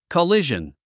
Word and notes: collision: kəlíʒən; コリジャン

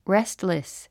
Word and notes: restless: réstlis; レストレス

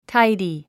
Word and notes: tidy: táidi; タイディ

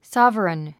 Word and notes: sovereign: sάvərən; サァバレン

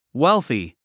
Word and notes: wealthy: wélθi; ウェルスィ

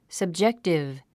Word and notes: subjective: səbdʒéktiv; サブジェクティブ

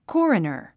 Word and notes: coroner: kɔ’ːrənə r; コーレナァ

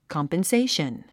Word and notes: compensation: kɑ`mpənséiʃən; コンペンセイション

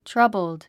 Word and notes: troubled: trʌ́bld; トゥラボゥド

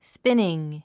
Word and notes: spinning: spíniŋ; スピニン

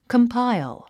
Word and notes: compile: kəmpáil; コンパァイォ

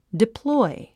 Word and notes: deploy: diplɔ́i; ディプロイ

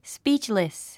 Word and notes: speechless: spíːtʃlis; スピーチリス

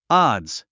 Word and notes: odds: άdz; アズ

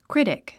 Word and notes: critic: krítik; クリティック

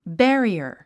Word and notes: barrier: bǽriə r; ベァリア

